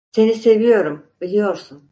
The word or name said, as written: seviyorum